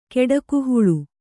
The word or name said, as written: ಕೆಡಕುಹುಳು